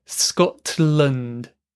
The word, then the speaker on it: Scotland: So we often use the glottal stop to make things easier to pronounce. I would say Scotland. Scotland. So compare uh-oh, Scotland. It's the same thing happening here, that glottal stop. But also notice you cannot really hear the T sound.